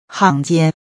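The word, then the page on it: 行间: xingjian; Sing tsienn; Hanh Gian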